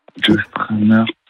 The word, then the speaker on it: Joe Strummer: Si je vous avais dit, je vais mal le prononcer, mais genre Joe Strummer. – Oui, Joe Strummer.